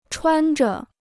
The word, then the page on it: 穿着: chuān zhuó